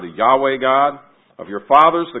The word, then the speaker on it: Yahweh: And he says, he uses that word that we pronounce like Yahweh.